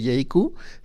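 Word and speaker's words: Jaecoo: Y bueno, para los que se preguntan cómo se escribe JQ, se escribe con JAECWO. No, porque sí, a la hora de buscarla en redes creo que es más fácil como claro, se escribe, porque se pronuncia pues un poquito diferente,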